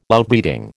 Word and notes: Outbreeding: American English

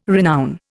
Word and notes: renown: Indian

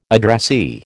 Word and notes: addressee: American English

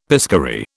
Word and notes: piscary: British English

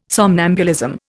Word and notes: somnambulism: Indian

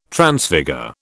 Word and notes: Transfigure: British English

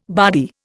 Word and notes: bardy: Indian

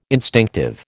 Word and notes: instinctive: American English

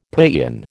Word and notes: paeon: American English

